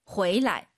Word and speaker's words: 回来: 호이라는 글자 뒤에 라이나 취가 오면은요. 방향보어로서 경성입니다. 호이취 해도 알아듣지만 호이취 돌아오다 호이라이 해도 되지만 回来. – 가 정확한 발음이에요.